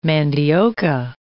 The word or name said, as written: mandioca